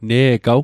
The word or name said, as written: ne'gaw